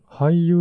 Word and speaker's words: Hy: 日本ハイユーザー会さん。